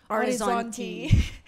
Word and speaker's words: Orizzonti: Well, Verdict also won the, how do you pronounce this? – Orizzonti.